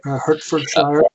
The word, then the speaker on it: Hertfordshire: Am I pronouncing that right? Hertfordshire? – Hertfordshire, but it's right.